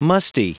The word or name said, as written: musty